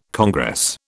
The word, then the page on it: congress: British English